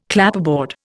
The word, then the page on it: Clapperboard: Indian